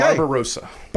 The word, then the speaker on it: Barbarossa: All right. Operation Barbossa. – Barbossa. Okay. – Barbarossa. – Barbarossa. Yeah. Okay. I was just going to say I'll never forget that, but I already said it wrong.